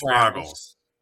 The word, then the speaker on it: Spraggles: I was not sure how it was pronounced, if it was a Spraggles or like Spraggles, like rhymes with bagels. So it is Spraggles.